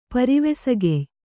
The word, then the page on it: ಪರಿವೆಸಗೆಯ್: Kannada